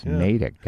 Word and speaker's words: Natick: Natick. – Oh, yeah. Yeah. Yep. Yeah, I mean, if there were two T's, that would be Natick, but it's Natick, guys.